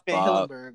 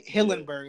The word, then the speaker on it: Hillenburg: We got a shout out to Steven Hilberg, you know, RP to that man, the legend. He gave us one of the best cartoons ever. – Yes, RP Steven Hillenburg, right? Hillenburg, yeah.